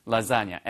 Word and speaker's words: lasagna: lasagna. L-A-S-A-G-N-A에서 발음은요. lasagna처럼 발음돼요.